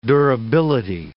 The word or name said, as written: durability